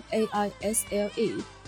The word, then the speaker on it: aisle: aisle a i s l e aisle 是靠走道的意思。这里的这个单词里的 s 不发音，所以是 aisle，aisle，aisle seat 靠走道的座位。